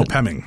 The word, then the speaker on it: Nopeming: Is it Nopeming? Is that how you pronounce it? – Yeah, Nopeming.